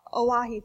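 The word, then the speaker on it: Oahe: Standing Rock Reservation is located just to the west of Lake, I think it's pronounced Owehu. Oahu, thank you.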